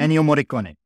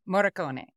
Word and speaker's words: Ennio Morricone: The music, you guys will remember when we talked about Ennio Morricone, Morricone, I don't know how to pronounce it. He's Italian. – Ennio Morricone. – Morricone.